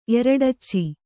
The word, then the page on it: ಎರಡಚ್ಚಿ: Kannada